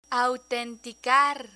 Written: autenticar

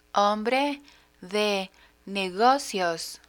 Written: hombre de negocios

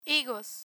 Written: higos

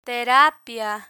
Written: terapia

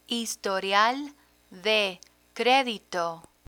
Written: historial de credito

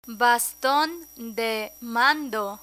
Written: baston de mando

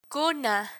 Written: cuna

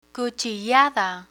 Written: cuchillada

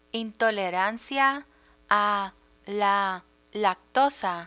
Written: intolerancia a la lactosa

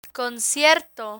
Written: concierto

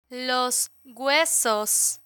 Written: los huesos

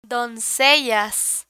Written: doncellas

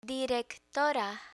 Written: directora

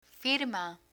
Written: firma

